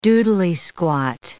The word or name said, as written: doodley-squat